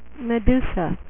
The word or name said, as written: medusa